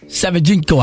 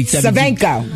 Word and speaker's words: Savchenko: I think that ain't how you say it. No. You tried it. How you say it? – Gleb Savichko. – Nope. – Savchenko, I bet you when you watch the show, you're going to say Savchenko.